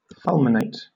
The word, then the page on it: fulminate: British English